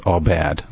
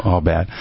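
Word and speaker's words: abad: So first of all, work and worship have the same Hebrew word in certain context, and it's A-B-A-D, and it's pronounced all bad, all bad.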